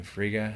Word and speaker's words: Frigga: Frigga.